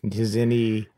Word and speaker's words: Nizhny: Nizhny Tagil.